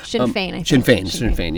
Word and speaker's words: Sinn Féin: And he later became head of Sinn Féin. Is that the correct pronunciation? – Yeah. – Sinn Féin.